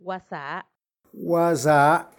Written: gwasaa

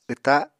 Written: wta

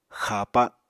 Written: xapa

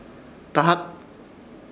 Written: Dahat

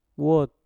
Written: wot

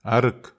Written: arek

